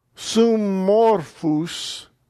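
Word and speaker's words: summorphus (συμμόρφους): Summorphus. Notice the holding of the double mem. Instead of sum, it's summorphus.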